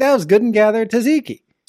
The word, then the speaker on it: tzatziki: We found last week, we were at Target, surprise, and we found some vegan tzatziki.